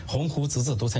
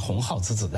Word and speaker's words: 鸿鹄之志: 我们正在讲北大的沉沦的时候，北大的校长就出来做了一个证明，以他读错一个非常常见的成语来做一个证明，鸿鹄之志读成鸿号之志，来号召学生立鸿号志，说成了天下的笑话。